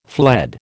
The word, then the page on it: fled: American English